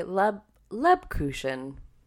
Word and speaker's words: Lebkuchen: So, it's this adorable Christmas village on the wrapper, and it says sugar glazed Lebkuchen? – Lebkuchen. – Lebkuchen. – Am I adding an extra K? Lebkuchen.